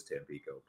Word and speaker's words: Tampico: I'm sure I'm saying that incorrectly. It's been a little while. I think it is Tampico, but I like that. – Correct.